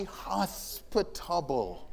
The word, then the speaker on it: hospitable: She's, uh, they love to be hospitable. Wait, wait, wait. What is, huh? sorry? Did I say it wrong? Oh, yeah. Hospitable. There's a spit in there.